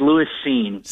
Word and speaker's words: Lewis Cine: What kind of camp has he had, and how do you pronounce his name? Because I think we're going to have to know that this year. – Sure, yeah, it's Lewis Cine.